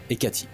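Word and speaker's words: Hecate: Hecat, je sais pas comment ça se prononce. En anglais, c'est on dit Hecati. Ekati, ok d'accord.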